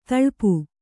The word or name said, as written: ಥಳಪು